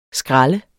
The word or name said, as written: skral